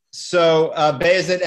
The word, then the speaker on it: Bayezid: So Bayezid and Jem is how the name is pronounced.